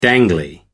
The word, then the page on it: dangly: American English